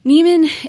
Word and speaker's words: Nieman: Can you spell Neiman out for everybody? – Neiman, it's pronounced like Neiman, like Neiman Marcus, but it's spelled N-I-E-M-A-N.